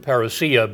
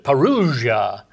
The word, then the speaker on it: parousia: I was listening to a man on the radio who was talking about this, and he liked the word parousia, but he pronounced it parousia. And the more of the Greek pronunciation, parousia.